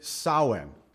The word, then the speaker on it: Samhain: A Celtic vigil pronounced by many as Samhain, S-A-M-H-A-I-N, or Samhain, but the Druids and the pagans, etc., pronounced it Samhain.